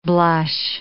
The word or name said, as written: blush